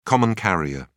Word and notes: common carrier: British English

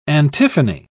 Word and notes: antiphony: British English

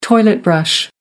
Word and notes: toilet brush: American English

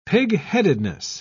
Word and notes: pigheadedness: British English

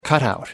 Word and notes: cutout: American English

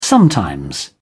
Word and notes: sometimes: British English

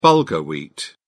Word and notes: bulgur: British English

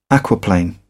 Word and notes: aquaplane: British English

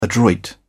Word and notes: adroit: British English

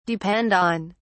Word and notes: depend on: British English

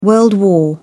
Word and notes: world war: British English

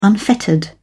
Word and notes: unfettered: British English

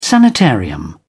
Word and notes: sanitarium: British English

